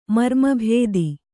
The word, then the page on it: ಮರ್ಮಭೇದ: marma bhēda